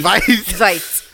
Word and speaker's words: Veidt: Is that how you pronounce his name? – I have no idea. – V-E-I-D-T. – Veidt.